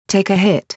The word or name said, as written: take a hit